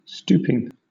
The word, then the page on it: stooping: British English